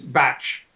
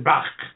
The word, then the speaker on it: Bach: I should just point out there, the proper pronunciation is batch flower remedies, because old Dr. Batch, although he was a Welshman and would have pronounced it Bach, he found that his fellow medical students in London couldn't pronounce his name properly. So he simply, from the rest of his life, from his medical student days onwards, told people to call him Batch.